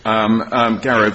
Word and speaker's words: Garrow: Garrow puts very specific requirements, and I hope I'm pronouncing Garrow correctly, Garrow v. Pulley. – Sounds correct when you say it.